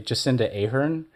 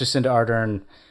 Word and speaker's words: Jacinda Ardern: It's been pointed out to me on various platforms that for some reason I continually say Jacinda Ardern, which I have no idea why I do that. But I'm using this opportunity to say I do know it's Jacinda Ardern.